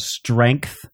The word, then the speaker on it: strength: For like a year and a half, whenever we talked about strength, Teddy would say strength. And I was like, listen, that's not how it's pronounced. There's a G in there. The E is not pronounced I.